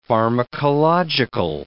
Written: pharmacological

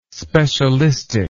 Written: specialistic

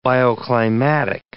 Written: bioclimatic

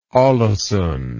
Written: aalesund

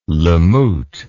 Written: lamut

